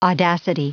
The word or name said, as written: audacity